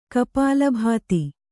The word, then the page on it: ಕಪಾಲಭಾತಿ: Kannada